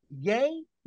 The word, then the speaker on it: Ye: How do you say his name? – Yay, as in Kanye West.